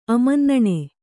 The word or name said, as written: ಅಮನ್ನಣೆ